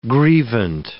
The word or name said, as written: grievant